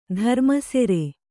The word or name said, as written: ಧರ್ಮಸೆರೆ